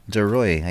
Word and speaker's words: Ruhe: Getting a little more active on Mastodon, which is mastodon.world slash at sign radio CBLUE mentioning a composer named Maria DeRoy. I think that's how you pronounce her name. It's uh, the letters DE and then the surname R U H E.